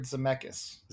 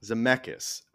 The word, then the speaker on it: Zemeckis: Robert Zemeckis. – Zemeckis.